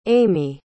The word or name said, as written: aime